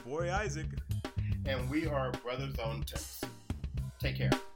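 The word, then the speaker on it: Bruthas: Brothers on Tennis.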